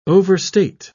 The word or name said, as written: overstate